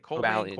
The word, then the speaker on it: Cobalion: Now, we do have Colbane on... Cobalion? How do you say it? – Cobalion. Yeah.